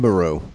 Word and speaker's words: Nibiru: Am I Nibiru. Pronouncing that correct? – Nibiru. Yeah.